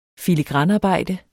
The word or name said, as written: filigranarbejde